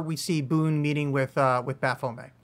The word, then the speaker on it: Baphomet: Baphomet, as I like to pronounce it, because the two pronunciations are equally correct, but I believe Baphomet's better because it does come from the French and there's no reason to call it May.